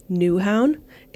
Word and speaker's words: Nyhavn: So the most popular street in the city is called Newhaun. It's spelled N-Y-H-A-V-N, and I would not stay here.